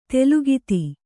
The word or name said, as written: ತಲೆಗುತ್ತ